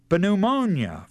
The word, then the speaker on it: pneumonia: And we take our English word pneumonia from pneuma, except we don't pronounce the P.